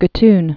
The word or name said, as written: Gatun Lake